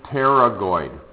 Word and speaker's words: pterygoid: and they're called the pterygoid process. Notice you do not pronounce the P, but when you spell it out, that P has got to be there.